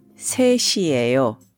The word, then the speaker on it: 셋이에요: Now, please note that 셋, 3, 이에요, the be verb, is combined and is pronounced 셋이에요. Separately, it's 셋 followed by the verb 이에요. Putting these together, they're pronounced 셋이에요.